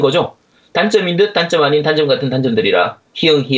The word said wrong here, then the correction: 히읗: 히응 히읗 이거 제가 자, 정확하게 말씀드릴게요. 히읗 히읗이라고 발음해야 돼요. 밑에 맞춤이 디귿입니다.